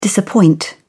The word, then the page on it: disappoint: British English